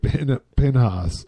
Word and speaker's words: Pinhas: I like Richard Penis. – It's Richard Pinhas.